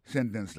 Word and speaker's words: sentence: On commence par moquer les sentences là. Sentence completion. Ça nous a dit dans la sentence, c'est que l'accent tombe sous sen, sous premier syllabe là.